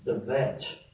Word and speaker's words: de Wette: In 1805, early 1800s, a German scholar named Wilhelm de Wet, that's spelled D-E-W-E-T-T-E,